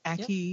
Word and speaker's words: ackee: So are there any, so ackee, is that how you say it? – Yep. – Ackee, yep.